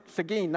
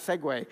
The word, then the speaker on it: Seguin: Even though I'm from Seguin, not Segway.